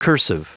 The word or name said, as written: cursive